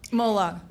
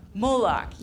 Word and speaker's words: Moloch: I know I'm saying it wrong. – Moloch. – Moloch, yes.